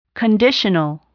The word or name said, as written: conditional